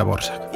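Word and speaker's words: Dvořák: No, sí, es la misma persona, pero se pronuncia Dvorak.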